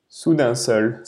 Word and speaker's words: Soudain Seul: How do you say it, Sudan? Soudain Seul.